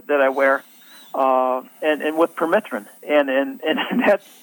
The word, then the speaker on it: permethrin: People think of spraying their pants and shoes with the permethrin.